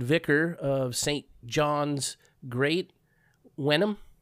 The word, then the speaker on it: Wenham: How do you spell that? W-E-N-H-A-M. – Wenham. That sounds about right, yeah.